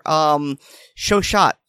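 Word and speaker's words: Chauchat: Shoshot, I think. – Yeah, it's pronounced Shauchat. – Yes, yeah, it's spelled Shoshot, but I believe it's pronounced Shosho, which it seems.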